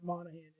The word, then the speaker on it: Monaghan: Facebook, you can literally search out my name Art Monaghan, and it's you know it's a silent G.